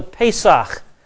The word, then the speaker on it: Pesach: This first sacrifice is called the Pesach, which means Hebrew for Passover. So Pesach is spelled P-E-S-A-C-H. This is the Pesach, P-E-S-A-C-H, also known as the Passover.